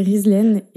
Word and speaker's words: Rizlen: Mais du coup, ils ont voulu me donner un prénom qui fasse autant euh, français à l'écrit que, euh, que marocain quand on le prononce, parce que Rizlen, c'est un prénom hyper commun au Maroc.